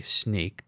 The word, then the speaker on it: Snyk: So the report was sponsored by Sneak, that's spelled S-N-Y-K.